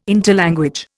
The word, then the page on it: interlanguage: Indian